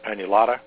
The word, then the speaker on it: annulata: But the Capillaria annulata. That's spelled A-N-N-U-L-A-T-A, and the Capillaria cantata. C-O-N-C-O-R-T-A.